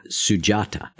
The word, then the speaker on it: Sujata: But you tell a story in there about a important part of the story of the Buddha, Sujata. Am I pronouncing that correctly? – Yes.